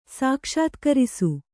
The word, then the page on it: ಸಾಕ್ಷಾತ್ಕರಿಸು: sākṣātkarisu